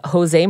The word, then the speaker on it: Jose: She spoke with Jose Martinez, who also you do not pronounce the J in, much like Fajitas.